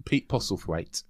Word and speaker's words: Pete Postlethwaite: Pete Postlethwaite.